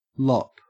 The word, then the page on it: lop: Australian English